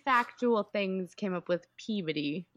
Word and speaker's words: Peabody: It's spelled P-E-A-B-O-D-Y. – Peabody. – That's cute. No. It's Peabody.